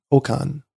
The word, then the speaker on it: Okan: H-A-G-I, Hagi, and then O-K-A-N. Oukan.